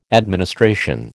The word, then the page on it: administration: American English